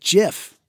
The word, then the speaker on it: GIF: But it's actually supposed to be pronounced JIF.